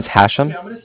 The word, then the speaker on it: Hashem: My name is Hashem. That's how do you spell that? – It's H-A-S-H-E-M, as in mother.